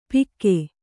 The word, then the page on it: ಪಿಕ್ಕೆ: kannada